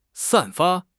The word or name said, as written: 散发